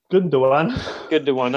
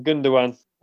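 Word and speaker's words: Gündoğan: Gundogan, that's the one. Gundogan.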